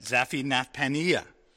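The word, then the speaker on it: Zaphenath-paneah: That's how you say it, Zaphinathpaneah.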